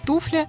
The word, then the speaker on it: туфля: В нашем случае во всех формах сохраняется ударение на «У» в этом слове. Туфли, туфельный там, и так далее. – А я еще помню, мы в школе учили такую запоминалку. Слово «туфля» я прочту с ударением на «у».